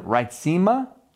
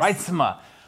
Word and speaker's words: Reitsema: We are so excited you're here with us, and we have somebody just absolutely tremendous with us, Lauren Reitsema. Did I say that correctly? – Close, Reitsema, yes. – Reitsema.